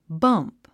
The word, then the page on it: bump: bʌ’mp; バンプ